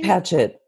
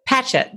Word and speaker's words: Patchett: Did you, as you were, it's funny, you're reminding me too of, I just read, listened to a podcast with Ann Pichette. I'm not, may not be saying, pronouncing her last name accurately. Is it Pichette or? – Ann Patchett. – Patchett. That's, I knew I was saying it wrong.